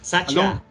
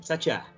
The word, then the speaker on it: Satya: Satya, é isso mesmo. Satya, confia.